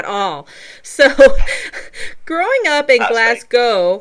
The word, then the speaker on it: Glasgow: No, you say it like most Americans normally say it before they're corrected. So it's Glasgow. So you kind of forget the W at the end, and it's like two words, Glasgow.